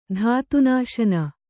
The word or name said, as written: ಧಾತುನಾಶನ